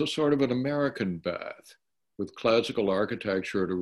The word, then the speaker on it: Bath: The British pronounce it Bath, but if you say Bath, they know what you're talking about. They just know you're a Yank.